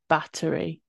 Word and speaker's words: Bathory: About Bathory, but I think you say it Battery. because it's Hungarian.